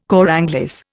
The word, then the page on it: cor anglais: Indian